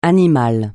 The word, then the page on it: animal: francais